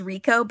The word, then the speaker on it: Reco: His name is Rico, but it's spelled R-E-C-O.